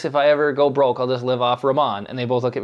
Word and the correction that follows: ramen: It's what? Ramon? Ramen? – Ramen.